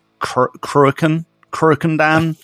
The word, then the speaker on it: Cruachan: I think it's pronounced Cruachan Dam. – That sounds right. – Yeah, but I keep getting it wrong anyway, so, you know, take that with a grain of salt.